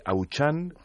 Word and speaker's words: Auchan: Que se escribe Auchan y que se pronuncia ¿cómo? – Sí, se pronuncia, la primera palabra es Auchan en francés, la segunda es en inglés Retail y la tercera en español España.